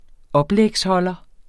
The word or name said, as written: oplægsholder